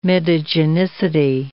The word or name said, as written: mitogenicity